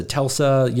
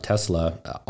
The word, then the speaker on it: Tesla: I literally I had a Tesla pitch deck because you know, they were mispronouncing the name. They're like, oh, is this a Telsa?